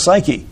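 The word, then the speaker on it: psyche (ψυχή): In reality, the words are synonymous because soul is the English translation of the Greek word psuche, which we pronounce psyche, and they mean exactly the same thing because they are synonyms.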